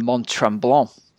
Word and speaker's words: Mont-Tremblant: And also Ironman Mont-Tremblant out in Canada.